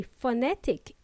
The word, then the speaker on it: phonetic: By the way, phonetic is spelled as P-H-O-N-E-T-I-C, and it's pronounced with an F. How ironic. We spell it with a P, P for Peter, and then we pronounce it with an F, F for Freddy.